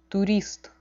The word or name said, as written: turist